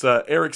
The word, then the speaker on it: Eric: Also, I got to point out, it's Eric spelled with a C, not a K.